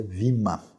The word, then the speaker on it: bema: E aí quando olhamos para essa palavra tribunal, nós temos uma palavrinha grega chamada bema, que no grego moderno se pronuncia vima.